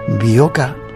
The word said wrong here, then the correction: Boika: Boika, Boika.